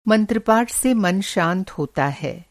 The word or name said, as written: मंत्रपाठ